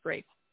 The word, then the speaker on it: Ralph: Like, Ralph? – None. It's pronounced Rafe. – Rafe? Ralph? Rafe? – It's Ralph Fiennes, yeah.